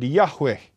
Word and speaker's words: Yahweh: YHWH, tetragramaton di dalam bahasa Yunaninya, ya, empat huruf suci ya, yang ditambahkan huruf hidup supaya bisa disebut, disebut diucapkan menjadi Yahweh, yaitu aku.